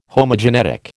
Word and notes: Homogenetic: American English

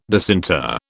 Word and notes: disinter: British English

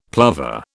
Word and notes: plover: British English

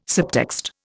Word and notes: subtext: Indian

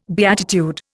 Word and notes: beatitude: Indian

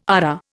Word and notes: ARA: Indian

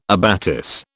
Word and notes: abatis: British English

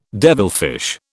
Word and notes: devilfish: British English

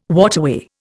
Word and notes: waterway: Indian

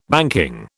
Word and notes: banking: British English

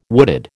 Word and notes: wooded: American English

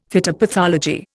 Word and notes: phytopathology: Indian

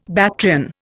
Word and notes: Bactrian: Indian

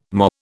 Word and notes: MOH: British English

